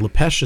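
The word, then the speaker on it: Lapêche: Lepesh is spelled L-A-P-E-C-H-E, like the peach in French.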